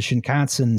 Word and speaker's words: Shinkansen: But the first, like, real dedicated high-speed train, you know, the real thing was the Shinkansen Zero Series, 1964. – Rhymes with Wisconsin.